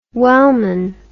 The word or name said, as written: Wellman